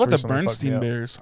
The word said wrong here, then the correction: Berenstain: Yeah, I spell like Stein, but it's Stain.